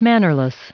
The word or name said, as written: mannerless